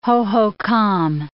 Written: Hohokam

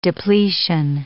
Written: depletion

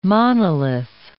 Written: monolith

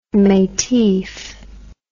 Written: metif

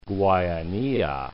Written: Guayanilla

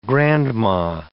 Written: grandma

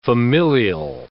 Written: familial

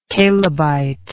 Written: Calebite

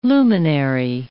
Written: luminary

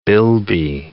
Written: bilby

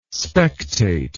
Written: spectate

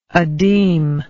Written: adeem

